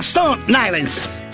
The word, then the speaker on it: Stone Island: Questi gym della Stone Islands. – Stone Islands. – Ma perché scrivono? Scrivono come si legge. – Si chiama così, Stone Island.